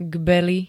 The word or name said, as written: Gbely